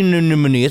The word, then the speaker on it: anemone: There's the sea anemone. It's what Nemo was in, right? – Yeah, I feel like you're saying it right.